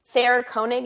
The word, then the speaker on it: Koenig: That's Sarah Koenig. Is that how you say her name? – Yes, I think that is. Yes, she's an incredible artist, and I think storytelling is a form of painting.